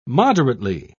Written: moderately

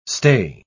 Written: stay

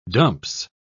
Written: dumps

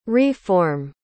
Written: re-form

